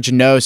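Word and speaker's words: Jeannot: I don't know how to say his last name right. You know, it's like Janot. – Janot? – What is it? – Oh, Tanner Janot. – Janot? Is it literally Janot? Janot. Okay, that's what I thought it was.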